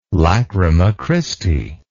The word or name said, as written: Lacrima Christi